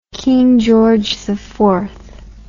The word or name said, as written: King George IV